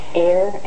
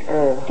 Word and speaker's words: err: That's what you breathe, air. But this word is pronounced er.